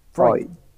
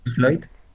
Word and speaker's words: Freud: Freud. – Freud.